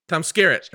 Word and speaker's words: Tom Skerritt: I don't know how to say this guy's name. Tom Skerritt. Tom Skerritt. That's how you say it.